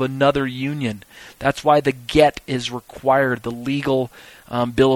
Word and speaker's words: get: Divorce in Judaism today is accomplished by the Bet Din through a document known in Hebrew as a get, and it's spelled G-E-T, just like get me a cup of water, get me a cup of tea, or get something.